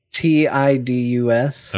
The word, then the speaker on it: Tidus: T I D U S.